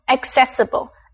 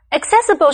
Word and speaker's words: accessible: 继续我们接下来看的是 accessible 这个形容词注意它的第一个C是发 k 的音第二个C是发 s 的音念成 accessible accessible